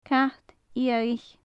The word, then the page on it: cat fhiadhaich: gaelic